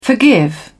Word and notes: forgive: British English

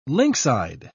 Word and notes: lynx-eyed: British English

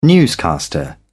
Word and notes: newscaster: British English

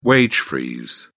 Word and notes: wage freeze: British English

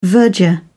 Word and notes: verger: British English

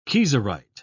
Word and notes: kieserite: British English

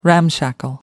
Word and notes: ramshackle: American English

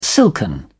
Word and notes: silken: British English

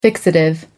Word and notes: fixative: American English

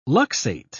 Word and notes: luxate: British English

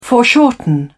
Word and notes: foreshorten: British English

